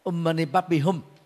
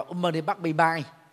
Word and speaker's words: Om Mani Padme Hum: Và khi làm thì bà đọc đó, chẳng hạn như là thay vì cái câu gốc của nó là Om Mani Padme Hum thì bà đọc là Om Mani Padme Mai, chứ hum thì bà đọc là chữ mai.